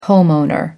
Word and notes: homeowner: American English

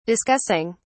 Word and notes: discussing: British English

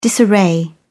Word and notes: disarray: British English

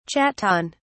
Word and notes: chaton: British English

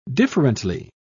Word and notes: differently: British English

